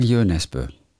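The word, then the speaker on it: Jo Nesbø: Wie spricht man den Autor aus? Jö Nesbø? – Ich würde sagen Jö Nesbø.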